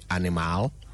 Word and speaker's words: animal: A-N-I-M-A-L. A-N-I-M-A-L. How do you say it? Do you say animal? Or like some friends of mine, do you say animal? Well, it's animal. Animal. The second A in that word is silent. So you don't actually sound it out.